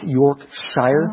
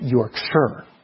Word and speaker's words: Yorkshire: You know that word, it looks like it should be pronounced Yorkshire.